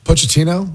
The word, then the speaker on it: Pochettino: By the way, Pochettino, is that you pronounce the coach's name? – Yeah.